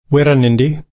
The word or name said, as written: wirranendi